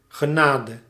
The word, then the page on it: mercy: /ˈmɝ.si/; /ˈmɜː.si/